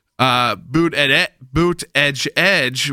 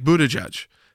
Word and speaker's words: Buttigieg: which is the boot edge, which is like the edge, and they're putting that in on shirts. When it's really more like Buttigieg, which is how, again, his husband has said it should be pronounced, which is a Buttigieg.